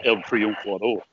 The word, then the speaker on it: El Triunfador: Uh, but Cubans they they would they would well actually if you want to say it in the Spanish language it'd be El Triunfador and if you really want to say it like a Cuban person, it's El Triunfador.